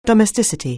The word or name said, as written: domesticity